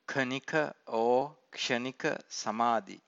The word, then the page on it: khanika-samadhi: Pali